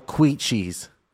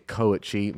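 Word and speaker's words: kouchi: I've been hitting the double queechies. – What's a double queechie? – It's actually a koichi, mispronounced by Gordon himself.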